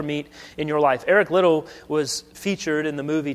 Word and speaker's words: Liddell: It's Little, not Liddell. It's spelled Liddell, but it's Little.